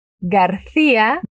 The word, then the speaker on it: García: García. Lleva tilde la I, por eso Ia.